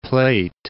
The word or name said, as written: River Plate